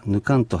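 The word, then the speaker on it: nukar: ぬからぬかんについてですが、ぬかららすいは発音しにくいので、ぬからがぬかんと発音されたものです。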